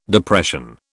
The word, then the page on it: Depression: British English